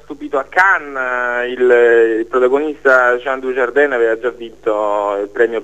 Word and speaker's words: Jean Dujardin: Gli attori, vedo qui Jean Dujardin, se pronuncio correttamente, sì.